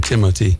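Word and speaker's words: Timothée: That's how he pronounces his name, Timothy.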